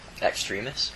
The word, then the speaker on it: Extremis: How do you pronounce it? – Extremis. – Oh, okay.